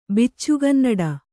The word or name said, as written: ಬಿಚ್ಚುಗನ್ನಡ